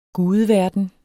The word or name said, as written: gudeverden